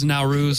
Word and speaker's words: Nowruz: So, how do you pronounce the name of this documentary? Nowruz? – Nowruz, same thing.